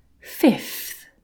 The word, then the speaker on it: fifth: First, third, fourth, fifth. Now the most accurate way to say this is so that is two letter F sounds and then one TH sound at the end.